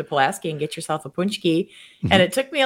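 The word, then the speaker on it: pączki: It still does to practice how to actually say it because it looks so different than the way you spell it. But punchki.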